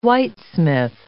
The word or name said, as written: whitesmith